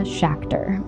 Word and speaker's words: Schachter: You can follow the podcast on Twitter and Instagram at ShareTheLoadPod, and you can follow me on Instagram at Mia Schachter. My last name is spelled S-C-H-A-C-H-T-E-R.